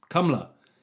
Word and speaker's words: Kamala: Well, let me tell you, like, his biggest problem, him and Kamala Harris, because apparently Brits aren't saying it right. We've got Kamala Harris. Kamala. It says Kamala, for fuck's sake.